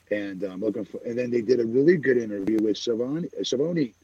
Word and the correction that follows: Schiavone: I keep saying his name wrong. Tony Schiavone today.